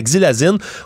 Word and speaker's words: xylazine: Évidemment, c'est un nom euh, qu'on utilise en raison des nécroses que ça provoque, cette Exilazine, je vais bien le prononcer, la xylazine, aussi appelée euh, sur la rue la trank, des tranquillisants qu'on utilise dans les domaines vétérinaires, entre autres, pour mhm.